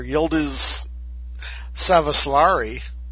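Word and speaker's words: Yıldız Savaşları: Again, I'll probably butcher the language here. Yıldız Savaslari. – That's exactly how you say it.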